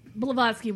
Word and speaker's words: Blavatsky: Blavatsky was awesome. – I'm so proud of you for saying that. I said it right.